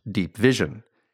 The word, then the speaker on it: Deep VZN: The program is called Deep VZN, which I believe is pronounced Deep Vision, and it's part of USAID, the agency which distributes most American foreign aid.